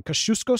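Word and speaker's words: Kościuszko: So much so that he actually establishes, and I'm about to butcher this, the Kosciuszko Squadron. Kosciuszko Squadron, I think.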